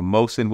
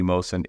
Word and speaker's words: Mohsin: And most people end up butchering my name to Motion. Whatever reason they say M-O, it becomes M-O-S-H-N. So what we've been able to develop because of DeepGram, from what I'm reading here in my notes, what our developers have done, is it's going to be able to correctly pronounce names. So Mohsin will be Mohsin, it will not be Motion.